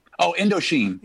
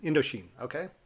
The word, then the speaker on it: Indochine: So if you can help me, if you know it, it's Indochine. – Oh, Indochine. – Indochine. Okay. Just the way it looks.